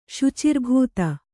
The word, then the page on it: ಶುಚಿರ್ಭೂತೆ: śucirbhūte